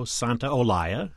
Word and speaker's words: Santaolalla: Brokeback Mountain by Gustavo Santaolalla.